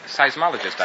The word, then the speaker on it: seismologist: Seismologist, I believe.